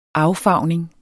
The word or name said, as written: affarvning